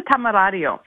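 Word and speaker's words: Temerario: Yeah, this is a Tamarario.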